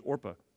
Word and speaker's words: Orpah: But it's very interesting that her given name is Orpah. It's actually, she's called Oprah because when she was young, so many people mispronounced it and they moved the P and the R.